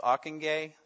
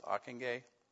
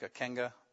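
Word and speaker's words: Ockenga: And some key figures in the evangelical movement, Harold Ockingay, that's how that's pronounced, Ockingay. Looks like a Kenga, but that's not it.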